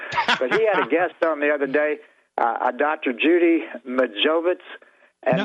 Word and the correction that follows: Mikovits: no, Judy Mikovits. – Okay, well, I didn't know if I was pronouncing it right or not. – Yeah, it's Mikovits.